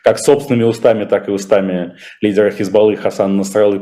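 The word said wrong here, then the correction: Насралла: подчерк Насралы, э, прошу прощения, меня уже указывали специалисты, арабисты, что именно так надо ставить ударение, э, говорит, что как-то лучше не становится вообще, честно говоря, от ударения Я прошу прощения, да.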